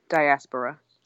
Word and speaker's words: diaspora: Well, how do you say that word? – Diaspora. – Okay, I was right the second time.